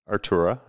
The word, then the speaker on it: Artura: So, is it Artura? – Artura, yeah. – Artura, is that what I'm saying it right? Mm-hmm. Okay.